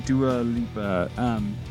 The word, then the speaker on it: Dua Lipa: I always say it wrong. Dua Lipa.